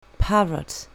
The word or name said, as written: parrot